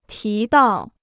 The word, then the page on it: 提到: tí dào